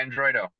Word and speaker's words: Androidoh: Yeah, Androidoh.